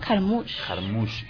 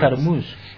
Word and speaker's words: Jarmusch: Jarmusch. – Que había hecho Coffee and Cigarettes. – Jarmusch.